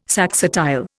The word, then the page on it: saxatile: Indian